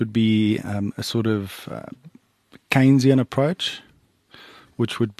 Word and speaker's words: Keynesian: There's Austrian school and is it? – Keynesian. – Keynesian, sorry, I always say that wrong.